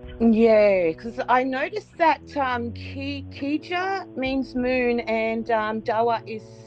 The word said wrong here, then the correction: kija: We pronounce Gija. The K actually makes a G sound.